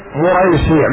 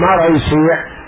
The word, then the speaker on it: مريسيع: بالضم فالفتح مصغرة، يعني هذه قراءتها بالضم الميم، مريسيع مهم مريسيع مريسيع ثم الراء مفتوحة بالضم فالفتح مصغرة، يعني نطقها نطق المصغر، مريسيع مثل قنيديل.